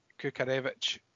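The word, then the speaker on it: Kukharevych: You said that Kukarevic, which is the correct way to say it, by the way, according to Hibs, you said that Kukarevic would be nowhere near the starting 11.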